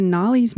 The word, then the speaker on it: Narli: So this particular track, I've really been getting into Gnarly's music. It's spelled N-A-R-L-I.